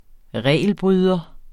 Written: regelbryder

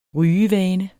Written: rygevane

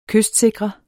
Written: kystsikre

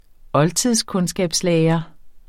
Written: oldtidskundskabslærer

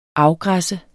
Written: afgræsse

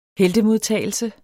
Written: heltemodtagelse

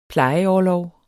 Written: plejeorlov

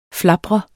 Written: flapre

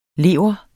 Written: lever

